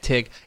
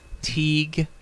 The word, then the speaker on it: Teague: I've been pronouncing it Teague, giving it the real, you know, cuz I think most people would look at it and say Teague, but much like Italy and stuff like that, it's Tig.